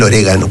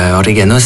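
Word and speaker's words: origan: ¿Cómo se dice orégano en francés? – Orégano se dice... – ¿Orégan? – Orégan, sí. – ¿Orégan? ¿Así? Sí, sí. ¿Como orégan?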